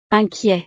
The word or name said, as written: inquiet